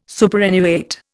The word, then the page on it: superannuate: Indian